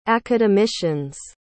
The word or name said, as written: academicians